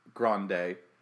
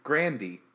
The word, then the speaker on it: Grande: It's not pronounced Grande. – Oh, how is it pronounced? – Apparently, it's Grandy.